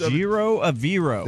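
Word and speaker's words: Ejiro Evero: Clint, just say it. – You said it right. – I did? – Yeah. It's E's and O's, baby. The rest Ejiro Eviro. – That's it. E's All right. and O's, baby. Yeah. – That's the Broncos E-O-E-O.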